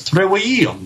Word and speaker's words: trivium: there's no V sound. It's trivium. That's how that word is actually pronounced.